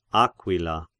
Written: Aquila

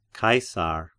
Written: Caesar

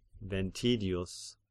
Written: Ventidius

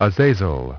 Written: Azazel

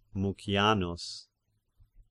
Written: Mucianus

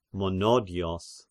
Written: Monodios

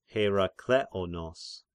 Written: Herakleonos

